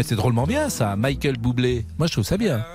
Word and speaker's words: Bublé: Bublé. – Bublé. – Voilà, un artiste pop jazz américain. – Alors ça m'avait échappé, Michael Bublé. Ça s'écrit comment? – B-U-B-L-E. – Voilà. B-U-B-L-E.